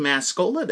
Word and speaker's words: Mascola: You just type my name, Andy Mascola, that's spelled M-A-S as in Sam, C-O-L-A as in Coca-Cola, into your search bar of your preferred online bookseller, and you'll find my work.